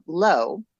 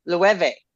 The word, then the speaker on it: Loewe: It is pronounced L-O-E-W-E. Mm-hmm. Well, I learned this weekend that it's a Spanish brand that's actually pronounced Lueve. – Oh, wow. – So saying it way wrong. Like, I heard a guy say it and I was like, wait, what now? And I went in and I found, and on YouTube, you can find many things of people pronouncing Lueve. So it is a Spanish word and it is not pronounced low.